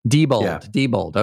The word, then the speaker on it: Diebold: Remember, there was some, again, my pronunciation, was it Diebold? – Diebold.